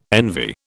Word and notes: envy: British English

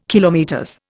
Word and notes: km: Indian

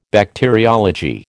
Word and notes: Bacteriology: American English

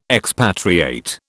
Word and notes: expatriate: British English

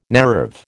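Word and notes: naïve: American English

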